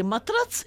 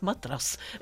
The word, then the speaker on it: матрас: э, когда-то писали матрац, сейчас правильно говорить матрас.